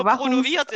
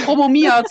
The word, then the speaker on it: promoviert: Du hast ja auch promoviert in Deutsch. Promomiert.